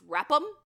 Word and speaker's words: RAPM: That RAPM should be pronounced Rap'em.